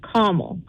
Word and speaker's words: Carmel: Well, I've only have said it that way since I've lived here, because I was told that's how you say it. It's caramel.